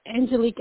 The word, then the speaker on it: Angelique: So it's Angelique Aguin. My first name is spelled A-N-G-E-L-I-Q-U-E. And then my last name is A-G-I-M.